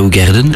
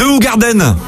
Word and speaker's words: Hoegaarden: Tu sais que pour apprécier le goût unique de la Hoegaarden, les Belges prononcent une à une chaque syllabe de son nom. – Ah bon? – Ouais, parce que le hou assouplit la bouche, le kaar dégage la gorge et le dun stimule la langue.